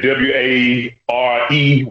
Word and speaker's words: Ware: W A R E Ware.